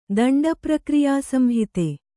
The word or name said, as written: ದಂಡಪ್ರಕ್ರಿಯಾಸಂಹಿತೆ